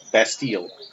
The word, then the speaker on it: Bastille: He had a secret warrant drawn for the child's arrest, and young Selton was thrown into a solitary confinement in his Bastille. I guess that's how you pronounce it, or Bastille? – Bastille.